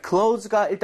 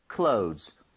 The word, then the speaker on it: clothes: 자, C-L-O-T-H-E-S 이게 옷이라는 뜻인데 발음 좀 주의하셔야 되는 게 clothes 그러니까 원래는 맞아요. – 아, 어려워요. – 그래서 원어민들은 정말 그 뭐라 그럴까 영상을 슬로우하게 돌려보면 아니 이게 보이긴 할 겁니다. – 진짜요? – Clothes. 그렇지만 이게 워낙 혀도 귀찮아하는 발음이에요. – 그죠. – 그래서 Z로 발음하셔도 문제가 없습니다. – 아. – So close 해도 소통하는 데 전혀 문제가 없으니까요.